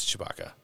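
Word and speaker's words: Tshbaka: No, it's T-S-H-B-A-K-A. – Oh, Chewbacca. – Yeah. – Like, but a sandwich. – How it's pronounced is Chewbacca.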